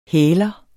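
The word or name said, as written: HÆLER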